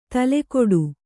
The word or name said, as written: ತುಲಾಕೋಟಿ